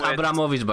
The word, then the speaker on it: Abramowitz: It's pronounced Abramowitz, by the way.